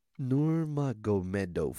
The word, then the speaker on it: Nurmagomedov: Khabib Nurmagomedov. – Nurmagomedov.